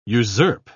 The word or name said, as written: usurp